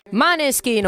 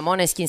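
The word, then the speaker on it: Måneskin: Maneskin o Moneskin, che dir si voglia, Moneskin sarebbe la corretta pronuncia.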